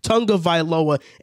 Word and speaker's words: Tagovailoa: It's Tua Tagovailoa.